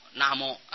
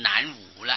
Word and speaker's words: 南无: 无不用无，用摸摸，用言字旁一个摸字的那个摸，那摸啊。那么这样子你就不会念成南无了哦。